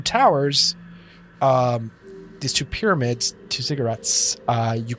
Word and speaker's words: ziggurats: So you guys are kind of standing in the southwest corner of these two fairly large cubic pyramids known as ziggurats. I mumbled that word, so I don't have to say it right. – You're saying it right.